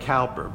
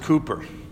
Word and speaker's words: Cowper: I remember how old poor, now this looks like Cowper, but it's pronounced Cooper. I remember how poor Cooper,